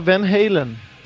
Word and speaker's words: Van Halen: Aqui é nos Estados Unidos é o normal é Van Halen. Van Halen, tá, A então... – pronúncia é americana, né?